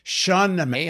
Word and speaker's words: Shahnameh: Shah means king. Shahnameh means book. Shahnameh, the Book of Kings.